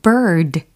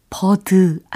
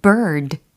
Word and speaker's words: bird: B-I-R-D. 이겁니다. 발음은 bird 라고 하고 계시죠? 버드 아니고 bird, bird, bird. 라고 해주시면 되겠습니다. 중간에 이 모음과 그리고 R 사운드가 이 중요한 부분이라고 보실 수가 있어요.